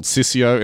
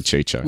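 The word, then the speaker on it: Ciccio: Now, we really butchered Ercole's name, as well as Chicho, who I called Sissio in that original episode, but the name was actually Chicho.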